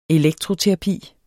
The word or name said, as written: elektroterapi